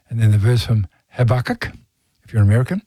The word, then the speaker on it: Habakkuk: The Americans pronounce the name of the Old Testament book Habakkuk. We say Habakkuk, but the other two books, most of us have the same pronunciation, Job and Revelation.